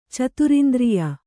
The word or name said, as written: ಚತುರಿಂದ್ರಿಯ